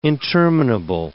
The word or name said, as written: interminable